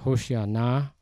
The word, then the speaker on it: Hoshana: The correct Hebrew pronunciation is Hoshana.